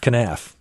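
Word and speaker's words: kenaf: I'm not even sure how to pronounce it. – Canaf? – Canaf, yeah.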